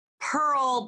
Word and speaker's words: Pirl: And it's, last name Pirl, but spelled like girl with a P.